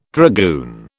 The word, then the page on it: dragoon: British English